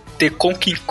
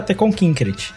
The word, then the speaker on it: Tekkonkinkreet: Hoje a gente vai falar de um filmezinho baseado no mangá do Taiyo Matsumoto, The Conking Gostei da pronúncia. Eu sempre gosto da pronúncia. É sempre muito precisa. Eu achei Sei. que era, eu, ach, eu, sempre achei que era Teconkinkrit.